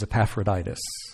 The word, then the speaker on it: Epaphroditus: If you ever come across his name in the scriptures and you wonder how in the world do you pronounce that, it is Epaphroditus.